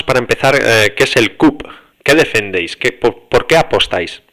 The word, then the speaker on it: CUP: Y ahora estás, creo que es la sexta o la séptima, por las listas de Barcelona de la CAP, ¿verdad? – La séptima. Sí. – La séptima. – No digas CAP porque es como si CUP. Lo pronunciaras en inglés. – CUP, perdón.